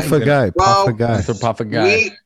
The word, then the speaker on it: Anthropophagi: No, it's Inbreeding the Anthropophagi. – Anthropophagi. Oh, see, he said it wrong. Yeah. Guy or Jai? – Guy. – Anthropophagi.